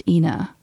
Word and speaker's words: Ina: So tell everybody you know that it's pronounced Ina. If you come to Tucson, you can visit Ina Road.